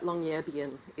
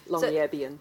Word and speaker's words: Longyearbyen: I'm going to get this wrong, and if there are any Norwegian speakers, please forgive me, but Longyearbyen is the way I understand it's pronounced. I think I may not have the accent in exactly the right place. Longyearbyen.